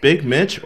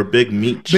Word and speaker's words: Big Meech: Is it pronounced Big Mitch or Big Meech? – Big Meech. Big Meech, yeah.